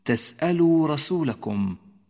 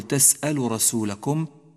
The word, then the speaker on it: تسألوا: أم تريدون أن تسألوا رسولكم، تسألوا رسولكم، لا تقل تسألوا رسولكم، لأنك بذلك تكون قد أهملت حرف الواو من النطق.